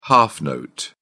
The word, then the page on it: half note: British English